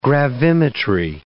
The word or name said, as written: gravimetry